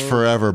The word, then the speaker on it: Forevr: That's spelled F-O-R-E-V-R. Forever. – Right, but it's pronounced Forever Bowl.